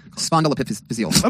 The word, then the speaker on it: spondyloepiphyseal: Spondyloepiphyseal? How do you say that? – Spondyloepiphyseal.